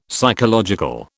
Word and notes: psychological: British English

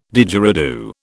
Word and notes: Didgeridoo: British English

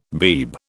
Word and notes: Beeb: British English

